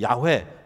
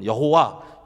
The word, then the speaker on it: 야훼: 자, 이 야훼라는 이름, 이 야훼 또는 여호와, 여호와라고 예전에는 이렇게 썼는데 이게 아마 야훼라는 발음이 아닐까 그래서 요새는 또 야훼 이렇게 불러요.